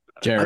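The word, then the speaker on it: Jarace: And if he goes four, like Walker, and I'm going to draw, how do you pronounce Walker's first name? – Jairus. I thought it was Jairus.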